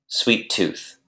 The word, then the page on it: sweet tooth: noun